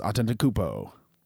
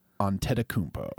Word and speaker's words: Antetokounmpo: Giannis Antetokounmpo. – I think you just mispronounced it. – Antetokounmpo.